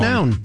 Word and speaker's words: McNown: McNown. – You know, I always say, because it's M-C-N-O-W-N, McNown. – Yes. So that is how I pronounce it and have pronounced it for my whole life.